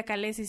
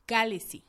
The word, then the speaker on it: Khaleesi: Que además dicen, te voy a dejar un artículo, dicen que el creador del lenguaje para la serie eh, dice que no se pronuncia calesi sino calesi, que lleva el acento en la antepenúltima sílaba.